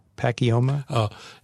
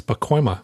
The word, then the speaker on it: Pacoima: And, well, it starts off, while we were all saddened to learn of the tragic death of this pilot in, how do you say the name of this place? Pacoima? – No. And this is something that I learned after I moved to California. It's Pacoima.